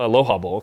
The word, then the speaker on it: Aloha: Plus, there's there's some uh, you know revenge factor there from the '92 Aloha Bowl. Aloha Bowl, excuse me. I pronounce it like the city in Oregon.